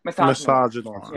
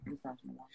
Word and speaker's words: Misogynoir: How do you say it? – Misogynoir. – Yeah. Misogynoir.